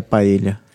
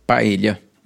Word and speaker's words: paella: Aliás, você, primeira coisa, como é que se pronuncia paella? Paella. Paella mesmo, né? – Eu não é soube é paella, isso. não. – Pa, é, eu soube que paella é na América do é. Sul, é. né? A pronúncia do espanhol aqui. É. E paella... – Paella é, é castelhano. – Mas que tem também a pronúncia paella. Sem ser o paella. Tem três pronúncias.